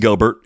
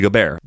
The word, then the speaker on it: Gobert: And it's like Rudy Gobert in the NBA. Same thing. Yeah. His name's not Rudy Gobert. It's Rudy Gobert.